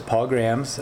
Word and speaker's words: Paul Grams: Well, my name is Paul Grams, P-A-U-L-G-R-A-M-S.